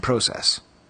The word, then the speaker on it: process: Anyways, as can be seen below, the Sears Canada ESB cardbacks are essentially cardbacks that were halted early in the production process. I pronounce that like a Canadian form. Process.